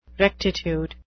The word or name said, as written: rectitude